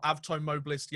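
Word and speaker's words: Avtomobilist: He is the captain of Avto Mobilist Yekaterinburg, if you want the official pronunciation.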